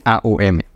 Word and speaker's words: AUM: Se escribe A-U-M, pero lo pronunciamos Om. Hay profesores que lo pronuncian A-U-M, ¿vale?